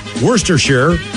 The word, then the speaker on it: Worcestershire: Again, the correct pronunciations, Worcestershire and Sriracha.